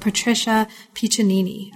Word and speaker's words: Patricia Piccinini: And this is an artist called Patricia Piccinini.